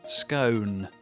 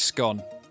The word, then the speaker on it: scone: I've always gone with scone. – Yeah, I'm the same. – The one that rhymes with gone with scone, that's the one that I've gone with, it's a scone.